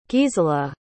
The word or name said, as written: gisela